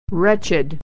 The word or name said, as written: wretched